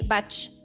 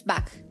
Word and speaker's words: Bach: Many people say Bach, but it's Bach, no? Mm-hmm. Well, the pronunciation is Bach.